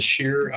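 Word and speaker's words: Shearer: My name is Nicholas Shear.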